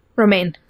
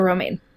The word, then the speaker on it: Romijn: Played by Rebecca Romijn? – Romijn. – Is that how you say it? Okay. Rebecca Romijn.